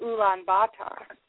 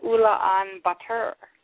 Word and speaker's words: Ulaanbaatar: And our favorite capital is of Mongolia, which is, we would have pronounced it Ulaanbaatar. And it was just such a cool name for a city. And she said, oh, no, that's not how you pronounce it. You're pronouncing it the way the Russians do. We pronounce it Ulaanbaatar.